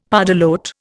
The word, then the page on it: pardalote: Indian